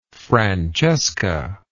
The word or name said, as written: francesca